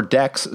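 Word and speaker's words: deque: Pronounced deck.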